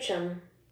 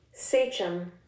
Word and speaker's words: sachem: Sachem. – Oh, I'm sorry. My mouth was in there. Do it again. – Sachem. – Sachem? – Sachem. – Sachem? – Sachem. S-A-C-H-E-M, but the pronunciation is S-A-Y-C-H-M.